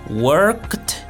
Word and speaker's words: worked: La pronunciación es de sonido de t. Por ejemplo, worked, worked.